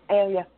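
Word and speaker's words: Ayla: And it wasn't at that point when I read it for the third time in my life that I realized that Aelia, is that how you say her name? Aelia? – Aelia, yeah.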